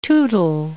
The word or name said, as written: tootle